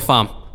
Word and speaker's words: Pham: Hi, I'm Michael Fahm. Last name is spelled P-H-A-M.